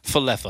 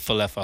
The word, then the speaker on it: Falefa: Is it Falefa or Falefa? He's like, Falefa. Falefa's good. Go with Falefa.